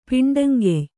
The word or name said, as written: ಪಿಂಡಂಗೆಯ್